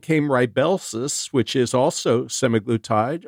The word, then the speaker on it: semaglutide: and they are singing the praises of semaglutide, or there's another way to pronounce it. – Semaglutide. – Semaglutide. – No, that's the way to pronounce it. Semaglutide, and the brand name is Ozempic and Wegovy.